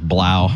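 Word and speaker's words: Blough: But I was swearing at David Blah. – Blah. – From dropping the S? – Purdue Boilermaker, blow. – Blows are going to blow. – The O-U-G-H is pronounced.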